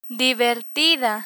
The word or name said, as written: divertida